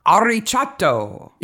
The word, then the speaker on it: arricciato: But there's a rolling R here. – Yeah. – Arricciato.